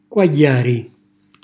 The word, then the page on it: quagghjàri: calabrese